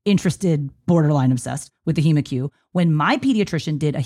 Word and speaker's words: HemoCue: He pronounces it HemaQ. I think in the United States, we pronounce it HemaQ.